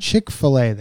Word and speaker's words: Chick-fil-A: Chick-fil-A, they call it, but it's spelled, you know how it's spelled. – Yeah. – F-I-L-A. Or like the pair of shoes.